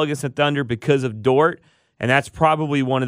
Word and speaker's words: Dort: They swept the season series, and they've got my boy named Dort, I believe is my pronunciation. – Lou Dort, yes.